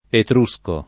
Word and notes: etrusco: lingua italiana

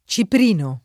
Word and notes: ciprino: lingua italiana

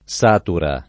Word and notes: satura: lingua italiana

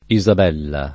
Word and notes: Isabella: [ i @ ab $ lla ]

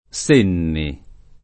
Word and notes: Senni: lingua italiana

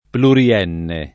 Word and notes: plurienne: lingua italiana

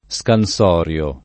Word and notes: scansorio: lingua italiana

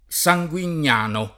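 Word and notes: Sanguignano: italiano

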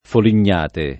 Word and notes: folignate: folin’n’ # te

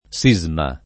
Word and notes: sisma: [ S&@ ma ]

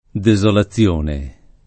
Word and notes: desolazione: lingua italiana

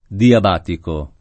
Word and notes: diabatico: lingua italiana

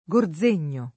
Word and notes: Gorzegno: italiana

